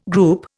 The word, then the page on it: group: Indian